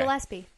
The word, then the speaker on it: Gillespie: I'm saying his name right? – Yeah, Dizzy okay. Gillespie.